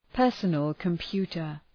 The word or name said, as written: personal computer